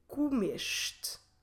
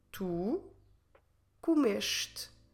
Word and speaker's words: comeste: we will have to add E-S-T-E, or in Portuguese, E-S-T-E. And we should pronounce comeste. Tu comeste. Naturally, when we're pronouncing it, we will drop the last E, or in Portuguese, E, of the word. Tu comeste.